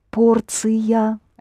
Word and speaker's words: порция: What will be the Russian for a portion? Шн, T-I-O-N. – Ция. Порция? – Да. Порция.